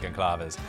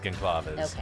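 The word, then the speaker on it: Gonçalves: Couple different ways, but I heard one where it seemed like people knew what they were talking about, and they pronounced it Gonclaves, and they had the dad on, and they introduced him as Gonclaves, okay. And he didn't correct them. – Okay. – So I'm sure if it's wrong, someone will correct us. Okay. But maybe just note that we think that's the pronunciation.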